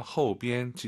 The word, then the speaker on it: 后边: 호우가 아니라 오유는 어우예요. 허우비엔. 지금 이제 일성으로 발음하셨는데 경성으로 그냥 后边.